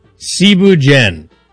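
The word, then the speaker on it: thiéboudienne: First off, Cebu Dien. It's spelt like Cebu Dien.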